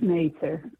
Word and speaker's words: Mather: It's Mather. That's the way to pronounce Mather.